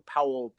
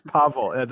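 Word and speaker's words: Paweł: All right, so my should win is for, and I hope I don't get this name wrong, Paul Pawlikowski. – You got it quite wrong. it's Pavel. The W is pronounced as a V.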